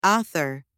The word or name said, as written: author